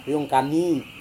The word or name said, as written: riungkami